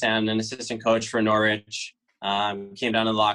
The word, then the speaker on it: Norwich: And you played four years at Norwich. Did I say that right? Because I think last time. – Yeah, you got it right.